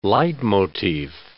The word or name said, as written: leitmotif